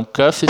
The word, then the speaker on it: cough: Então, cuff se escreve C-O-U-G-H. Só que a pronúncia dele é cuff, com som de F no final. Esse F não é pronunciado. Só sai ar quando a gente faz o F do F, cuff. Então, ele também segue essa regra. Então, cuff, o passado de cuff, escreve C-O-U-G-H-E-D, mas se lê cuffed.